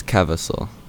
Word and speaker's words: Kawaso: Yeah, it's Kawaso. So yeah, you did it right. You did it better than most people do it. Most people say like Savasol or something.